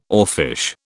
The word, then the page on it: oarfish: British English